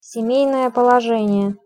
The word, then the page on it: semejnoe polozenie: Russian